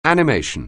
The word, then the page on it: animation: anglais